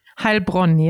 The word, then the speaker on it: Heilbronn: Would you pronounce that for me? – Heilbronn, yeah.